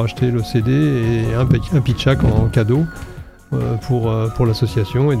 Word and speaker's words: Pitchak: Pitchak France Solidaire, qui est le nom de l'association en fait. – Donc ça s'écrit comme ça se prononce. – Voilà, comme ça se prononce, P-I-T-C-H-A-K.